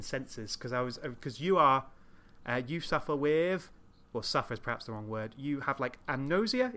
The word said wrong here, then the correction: anosmia: is it? How do you pronounce it again? – Anosmia. – Anosmia.